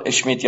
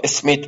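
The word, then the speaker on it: Schmitt: کارل اشمیت یا اسمیت به تلفظی در واقع دنبال میکنه.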